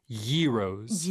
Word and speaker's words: gyros: But I think the correct Greek pronunciation is closer to gyros.